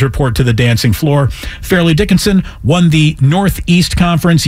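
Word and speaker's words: Fairleigh: I believe they call it Farley. – Farley. Spelled fairly. – Yes. – Call it Farley. Farley Dickinson. Not Mr. Furley.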